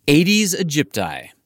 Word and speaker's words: Aedes aegypti: Leslie Vossall spends most of her time studying one mosquito species in particular, Aedes aegypti. That's two Latin words, Aedes, A-E-D-E-S, and aegypti, spelled like A-Egypt-I.